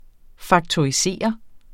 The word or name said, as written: faktorisere